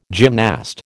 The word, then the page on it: gymnast: American English